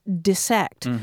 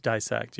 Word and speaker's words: dissect: I mean, I had a biology teacher in junior high who got all over us because she was saying, look, it's got two S's in it. It's pronounced dissect. But that one has definitely flipped. – Right. So it's dissect.